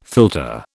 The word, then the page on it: philtre: British English